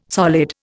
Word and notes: solid: Indian

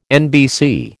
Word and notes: NBC: American English

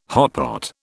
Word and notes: hotpot: British English

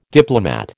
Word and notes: diplomat: American English

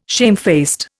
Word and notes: shamefaced: Indian

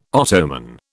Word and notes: Ottoman: British English

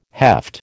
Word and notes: heft: American English

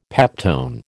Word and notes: peptone: American English

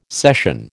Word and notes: session: American English

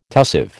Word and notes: tussive: American English

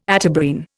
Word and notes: Atabrine: Indian

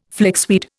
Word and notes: flixweed: Indian

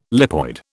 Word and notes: lipoid: British English

